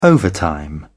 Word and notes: overtime: British English

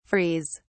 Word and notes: frees: British English

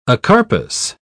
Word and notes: acarpous: British English

